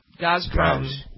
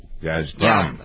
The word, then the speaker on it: Gazprom: Gazprom? Is that it? – Gazprom. – Gazprom.